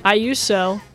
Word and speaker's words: Iuso: 9 o'clock at Tipitina's, Billy Iuso. – Iuso. – I'm using the Japanese pronunciation, man.